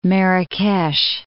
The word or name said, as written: Marrakesh